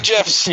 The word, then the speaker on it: gif: You and your northern hard G's. – I'm a hard G guy. It's gif. You know, if you wanted to call it a gif, perhaps he should have thrown in a J.